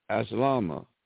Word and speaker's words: aslama: The root word of al-Islam is the word aslama. I'll spell it in the transliterated spelling, A-S-L-A-M-A, aslama, which means to enter into peace.